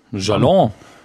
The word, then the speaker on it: Galant: Bodine Monet, hoffentlich richtig ausgesprochen, und ein Elektropop-Duo aus Süddeutschland namens Galant. Galant? – Galant. Kenne ich nur als Namen für Schreibtische und Autos. Also ich weiß nicht, ob es deutsch ausgesprochen wird, aber. – Jalon.